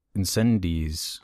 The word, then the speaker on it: Incendies: Um, I want to talk about Incendies. So you say it? Nice. – Oh. – It's uh, pronounced incendie. Incendie.